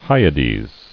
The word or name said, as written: Hyads